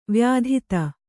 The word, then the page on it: ವ್ಯಥಿತ: vyathita